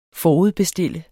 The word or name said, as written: FORUDBESTILT